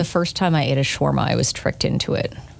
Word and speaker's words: shawarma: Shawarma Day.